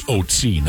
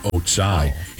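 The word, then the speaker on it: Ötzi: He says the mummy is, the pronunciation of its name is Otsi, not Otsai.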